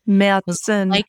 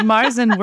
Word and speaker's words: Märzen: Is that how you say it? – If you're in German... – Okay. – But Marzen works great. In fact, if you're ordering it at an American restaurant, I highly recommend calling it Marzen, because the waitress might actually know what you're talking about.